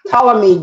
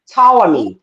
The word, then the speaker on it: Ptolemy: Ptolemy Gray. Ptolemy. It's spelled with a P, but it's Ptolemy Gray.